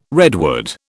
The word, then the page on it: redwood: British English